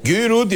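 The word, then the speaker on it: Guy Roux: Güyürü diye okunuyor değil mi? – Evet. – Çünkü Kemal Bey'in şeyi yabancı dili Fransızcadır.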